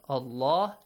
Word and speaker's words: Allah: The word Allah, sometimes it is pronounced with a heavy sound. Allah.